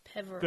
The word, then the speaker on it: Peverell: Peverell.